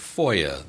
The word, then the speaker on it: FOIA: Just prior to the break, we were talking about FOIA. That's the pronunciation of the acronym FOIA that stands for the Freedom of Information Act.